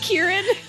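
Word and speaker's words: Ciarán: We've been saying that the ass butthole white that is Syrian Heinz. – Sorry. – His name is actually pronounced Kieran.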